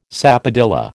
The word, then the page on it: sapodilla: American English